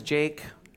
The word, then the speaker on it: Jake: I didn't wrestle, I didn't even fight, nothing like that, but because Jake rhymes with snake, I was called Jake the Snake.